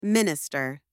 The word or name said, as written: minister